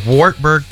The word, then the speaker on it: Wartburg: Wartburg College. That's how you pronounce it.